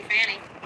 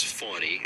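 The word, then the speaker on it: Fani: So thank you, Fanny. – First off, her name is Fawny, and they intentionally mispronounce her name over and over again.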